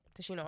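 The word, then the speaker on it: Tichina: Tashina Arnold.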